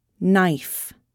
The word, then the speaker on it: knife: The letter K is not pronounced when followed by N at the beginning of a word. Knife, knee, know, knowledge.